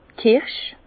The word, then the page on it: d' Kirsch: Bas Rhin; Herrlisheim